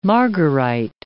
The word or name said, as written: margarite